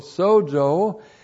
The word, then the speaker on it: sozo: Looks like that in the Greek, S long O, Z long O, sozo.